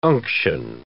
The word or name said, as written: unction